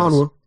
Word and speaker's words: Okongwu: I go back to Okongwu, and if I'm mispronouncing it, I apologize. – No, Okongwu. Yeah.